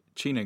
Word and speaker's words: Ciner: AKA Q Soda, AKA a subsidiary of the China Group, a Turkish C-I-N-E-R. – Okay. – I've taken the pronunciation from previous Liverpool midfielder Emre Can, so I hope it's right.